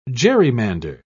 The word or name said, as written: gerrymander